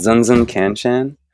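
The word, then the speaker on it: Znzn Kanshan: So the band is called Zun Zun Can Chan. We always get flack for it being hard to pronounce, but it's fun for us. Mm-hmm. It's spelled Z-N-Z-N space K-A-N-S-H-A-N. Sometimes we spell it other ways, but that's the main spelling.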